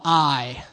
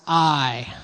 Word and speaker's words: Ai: All right, so they just had this massive victory in the city of Jericho, and they go to a place called Ai. Say Ai. Now, in your Bible, it will be Ai, but it can be pronounced Ai.